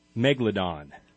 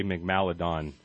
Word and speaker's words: Megalodon: Okay, so my cryptid is, and I always say it wrong, Megalodon, giant shark, which I normally say Megmaladon. – Megmaladon.